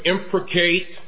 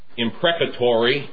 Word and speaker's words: imprecate: The verb to imprecate, and I pause again to note, my understandings were so shallow, I couldn't even pronounce it. It was imprecatory in my old Virginian. Imprecate is proper.